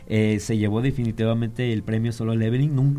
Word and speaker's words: Solo Leveling: Solo Leveling.